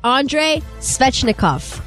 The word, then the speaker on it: Andrei Svechnikov: Andrei Svechnikov.